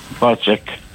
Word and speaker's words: Pesach: Pesach. – Ja. – Pesach står her, da. Det er sikkert uttalet, sikkert sånn som du sa. P-E-S-A-C-H, ikke sant? – Ja.